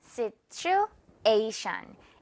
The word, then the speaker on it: situation: Situation. A 부분에 강하게 자 듣고 따라해 주세요.